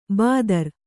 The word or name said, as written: ಭದ್ರೆ